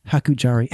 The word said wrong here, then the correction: Harajuku: Anyway. They go Harajuku? – Yeah, there you go. Harajuku, yeah. And they get up with the colorful outfits. – I'm saying it wrong, but... – But you got it right. You got it faster than I did.